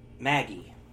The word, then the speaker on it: Magi: Maggie, which is Magi, but, man, the Japanese don't have a soft G, so it's Maggie, which is a really excellent story, and I'm buying the volumes.